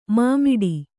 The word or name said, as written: ಮೂಮಡಿ